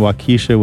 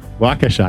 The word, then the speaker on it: Waukesha: Christopher in Waukesha, Wisconsin. Did I say that right? Thank you for your donation. – Waukesha, I got that one wrong.